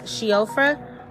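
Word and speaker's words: Síofra: So for Irish Celtic folklore, it's either pronounced Sciofra or Changeling, which Sciofra just translates to Changeling in their language, okay?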